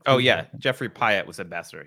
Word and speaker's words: Pyatt: Pratt. Jeffrey Pyatt, it's spelled. – Yeah. – Pyatt, P-Y-A-T-T, right? So, mm-hmm.